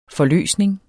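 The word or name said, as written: forløsning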